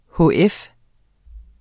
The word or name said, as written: hf